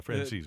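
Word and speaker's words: Franzese: And at CBS Sport, Dennis Dodd links an article to, and I always get this guy's last name wrong, Michael, is it Francisi, or Friends? – I thought that was it, yeah, Francisi.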